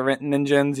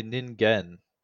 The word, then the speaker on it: ningen: So the next one, do you have anything you want to toss anything else in the ring about the ninjins? You got anything else? – I don't think this is pronounced ninjin. – Oh no? How is it pronounced? – If it's a G, it's probably ningen.